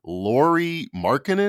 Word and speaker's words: Lauri Markkanen: And RJ said, "Why did you include Lori Mark? Lori? he probably didn't know how to pronounce the name. Lauri Markkanen."